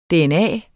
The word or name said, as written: dna